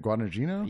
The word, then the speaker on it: Guadagnino: Uh, uh Jared, Guadagnino.